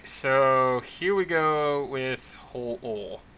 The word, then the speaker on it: Ho-Oh: Because in the chat there, Ho-Oh, the Pokemon, is spelled H-O-O-H, whereas the spelling of the actual yokai itself is just Ho-Oh, without the H. – With a long O.